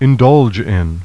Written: indulge

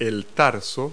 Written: tarsus

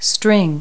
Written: string

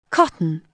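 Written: cotton

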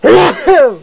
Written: hay fever

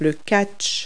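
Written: wrestling

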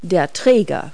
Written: girder

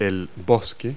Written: forest

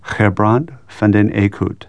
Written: Eeckhout

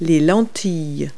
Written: lentils

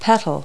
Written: petal

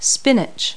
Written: spinach

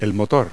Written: engine

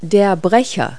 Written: breaker

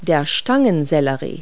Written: celery

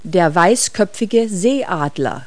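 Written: bald eagle

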